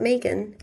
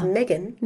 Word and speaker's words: Meghan: It's not Megan, it's Meghan. – Meghan. – We're the only country apparently that says Meghan.